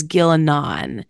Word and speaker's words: Ghilan'nain: So I pronounce that as Ghilan'nain.